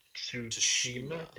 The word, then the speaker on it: Tsushima: And they've got Sucker Punch, who developed Infamous, and Sucker Punch are working on Ghost of Tsushima. Did I say that right? – Yep, I think so.